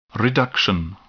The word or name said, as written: reduction